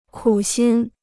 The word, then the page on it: 苦心: kǔ xīn